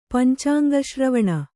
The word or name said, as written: ಪಂಚಾಂಗಶ್ರವಣ